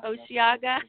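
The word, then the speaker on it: Osheaga: How do you say that? Osaga, which is Oceaga.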